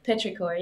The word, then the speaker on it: petrichor: Oh, Petrichor, yeah.